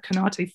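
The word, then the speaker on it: Konaté: God, I don't know whether you've seen the reports about Konate, Ibrahima Konate, from whether I'm pronouncing his name right, I don't know. But from I think it's right, yeah.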